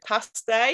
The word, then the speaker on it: paste: Real del Monte in Hidalgo in Mexico, they call it paste, and it's spelled P-A-S-T-E, but they put the accent on the E at the end.